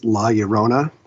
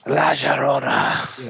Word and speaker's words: La Llorona: But the other one is La Llorona. And I'm saying that correctly this time, everybody. – La Llorona. – Yeah, because I made the mistake of saying La Llorona on YouTube.